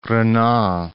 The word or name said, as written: Renan